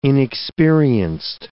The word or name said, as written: inexperienced